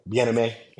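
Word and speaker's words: Bien-Aimé: I was like, it's not pronounced like Eric Bieniemy, and then of course immediately I just said Eric. – It's all good. Bieniemy.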